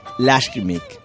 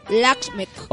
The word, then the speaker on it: Lakshmi: Lakshmi. – Lakshmi.